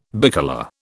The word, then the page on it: bicolour: British English